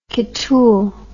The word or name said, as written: kittul